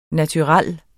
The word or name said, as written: NATURELLERNES